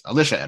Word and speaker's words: Alicia: Alicia Edwards. It's a short I.